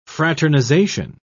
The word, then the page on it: fraternisation: British English